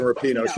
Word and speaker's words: Rapinoe: Megan Rapino. She always says Rapone. – And it's just like it. You say it just like it's spelled, for some reason have a hard time.